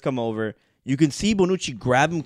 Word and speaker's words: Bonucci: Bonucci.